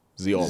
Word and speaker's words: Zeoli: Z-E-O-L-I. Zioeli. – Z-E-O-L-I.